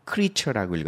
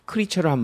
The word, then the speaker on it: creature: 근데 명사를 쓸 때는 크리처라고 읽지 크리에이처라고 읽으면 안 돼요. 발음이 creature라고 읽어야 돼요. 여러분, creature를 한번 쫓아 읽어보세요.